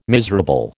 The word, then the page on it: miserable: American English